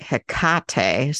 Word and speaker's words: Hecate: I have heard that in, if you're Greek, they usually do an emphasis on, um, so instead of it being Hecate, Hecate, it's like Hecate. So like the emphasis is on the second syllable.